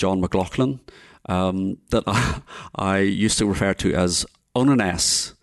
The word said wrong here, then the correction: Oneness: Until somebody pointed out to me that it's actually Oneness is the...